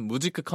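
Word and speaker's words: Musik: 무직회사는 무직크 컴퍼니라는 이름의 네. 그 이름을 이제 한글로 무직회사라고 한 거였어요. 네. 그래서 무직크는 이제 독일어로 뮤직의 발음이고요.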